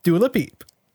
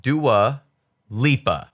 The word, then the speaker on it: Dua Lipa: Dua Peep. – What the fuck, dude? – I know this Her name is pronounced Dua Lipa.